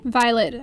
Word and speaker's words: Vilate: V-I-L-A-T-E. – Violet. I think it's pronounced Violet. – Oh, Violet.